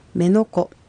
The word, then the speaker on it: メノコ: メノコの場合は第一音節は ME ですので、母音で終わっています。母音で終わる場合は第二音節にアクセントが来るので、メノコという発音になります。